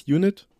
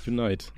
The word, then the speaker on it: Unite: Unit wäre ohne E am Ende. Unite, ja.